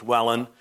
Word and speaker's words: Welwyn: Wellen is spelled W-E-L-W-Y-N, but it's pronounced not Wellen, it's Wellen.